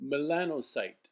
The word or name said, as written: Melanocyte